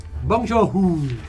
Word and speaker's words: Bong Joon-ho: Si pronuncia? – Bong Joon-ho. – Perfetto.